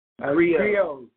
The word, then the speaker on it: Griots: We're proud to claim you because you guys definitely are awesome in this this album, American Griots. Did I say it right? – Griots, Griots, yeah.